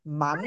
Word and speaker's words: Mann: Mann. Hi. you pronounce it man or man or? – Oh, man.